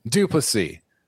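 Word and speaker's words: du Plessis: Duplessis.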